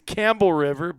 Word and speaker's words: Campbell River: It's Campbell River, but it's spelled Campbell River.